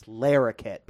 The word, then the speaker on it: Larroquette: No. It's Larroquette, people. – Oh, come on. No, it's, I mean, yeah, you got it. But come on, let's pronounce the guy's name right.